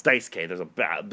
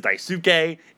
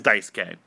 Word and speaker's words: Daisuke: That's how you say it. It's Daisuke. There's a fucking baseball player. I used to say it as Daisuke. It is Daisuke.